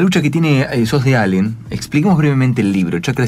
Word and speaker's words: Allen: Yo nací en Allen, así se pronuncia,